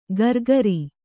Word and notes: ಕರಕರಿ: Kannada

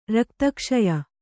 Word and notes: ರಕ್ತಕ್ಷಯ: rakta kṣaya

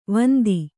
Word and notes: ವಂದಿ: vandi

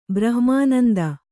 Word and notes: ಬ್ರಹ್ಮಾನಂದ: brahma'nanda

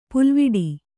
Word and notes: ಪುಲ್ವಿಡಿ: pulviḍi

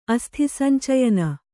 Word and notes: ಅಸ್ಥಿಸಂಚಯನ: kannada

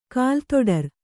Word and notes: ಕಾಲುತೊಡರು: kālu toḍaru

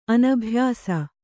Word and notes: ಅನಭ್ಯಾಸ: anabhyāsa

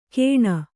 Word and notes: ಕೇಣ: Kannada

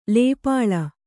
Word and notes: ಲೇಪಾಳ: lēpāḷa

